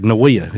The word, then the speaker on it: NAWEA: But NAWEA is what it's pronounced, but it's for wildlife officers and game wardens all across North America, Canada, United States, Alaska, everywhere.